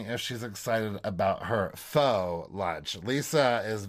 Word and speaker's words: faux: I pronounce it the way I see it, and I need to remember it's faux.